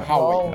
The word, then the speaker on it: Huawei: Se pronuncia é, Huawei.